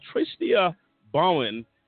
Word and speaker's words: Tristia Bauman: He's back with us, and we're bringing in now Tristia Bauman, uh, the senior attorney at National Law Center on Homelessness and Poverty.